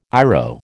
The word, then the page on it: IRO: American English